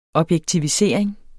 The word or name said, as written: objektivisering